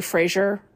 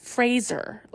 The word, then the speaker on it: Fraser: I saw him in Encino Man, but the first movie I really remember being aware of Brendan Fraser, I have to be careful because I want to say Fraser so badly, but I watched a video and he said that it's Fraser, like Razor, not Fraser.